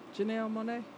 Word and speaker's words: Janelle Monáe: Is that how you say her name? – Electric Lady. – That's not how you say her name. – How I say it. – Say it again. – Janelle Monáe. – Oh, okay. You said it right.